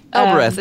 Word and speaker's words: Elbereth: Elbereth.